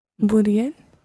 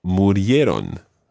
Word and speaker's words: murieron: So, they died would be? – Murieron. – Murieron. – Murieron. – Murieron en 2003? – Murieron en 2003.